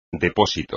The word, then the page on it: depósito: espagnol